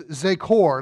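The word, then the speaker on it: zākor: The Hebrew word here is zekor. Let me spell it for you. Z, long A, K-O-R. Z, long A. That don't mean write your A long. That means write an A with a long line over the top of it. Good job. And K-O-R.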